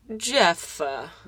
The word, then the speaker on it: Jephthah: In particular, he wrote one called, okay, it's spelled J E P H T H A H. – Jephtha. – Jephtha? That's what I was thinking.